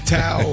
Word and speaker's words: tau: I only say Tao because otherwise it sounds like thou.